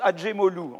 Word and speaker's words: Acemoglu: Donc, Acemoglu, on prononce Acemoglu, en fait.